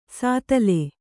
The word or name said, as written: ಸ್ಥಾಲಿ